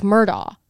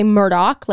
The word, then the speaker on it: Murdaugh: It's like, it's spelled like Murdaugh. Like you would think it's a G-H, right? So you would think that it's like silent, like Murdaugh. But it's actually, they say Murdaugh, like Rupert Murdaugh. But it's not spelled the same way at all.